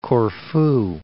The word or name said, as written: Corfu